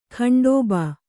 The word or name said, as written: ಖಂಡೋಬಾ